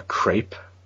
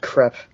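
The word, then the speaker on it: crêpe: Does he have an arch enemy who's like an evil baker called the Crepe? – No, that would be pretty cool. but sadly, it's pronounced Crepe.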